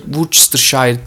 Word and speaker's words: Worcestershire: Worcestershire, tam telaffuzu o şekilde olması lazım. Worcester diye geçiyor.